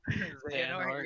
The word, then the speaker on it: Xehanort: Xehanort, yeah.